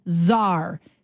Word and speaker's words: czar: The other word that starts with C is czar. C-Z-A-R. There's tons of talk in our province and our country about getting a border czar, a fentanyl czar. And it's got the silent letter, right? C is silent.